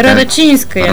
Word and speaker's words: Радочинская: Това е една рускиня, Виктория Радочинска. Я не знам как се чете името и трябва да е, ако ударението е на И, така, Радочинская.